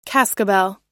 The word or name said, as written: cascabel